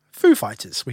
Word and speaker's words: Foo Fighters: What word is the pronunciation on? Is it on the Foo or is it on the Fighters? Now, we all know the pronunciation's on the Foo. Foo Fighters, we say.